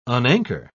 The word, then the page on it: unanchor: British English